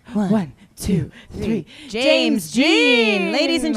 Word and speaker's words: Jean: It's James Jean. It's not James Dean.